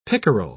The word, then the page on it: pickerel: British English